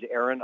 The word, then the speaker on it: Aaron: My name is Aaron Alenta. It's spelled A-A-R-O-N. Last name is A-U-L-E-N-T-A.